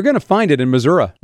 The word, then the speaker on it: Missouri: Missouri. That's how they say it, right? – Yeah. – Yeah. – That's right.